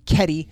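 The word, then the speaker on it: Kedi: You are going to love this movie, Keddie.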